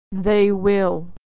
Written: they will

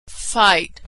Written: fight

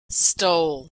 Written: stole

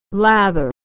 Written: lather

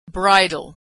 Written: bridal

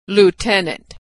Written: lieutenant

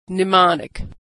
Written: mnemonic

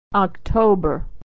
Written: October

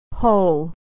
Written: whole

